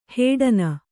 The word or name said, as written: ಹಡಣ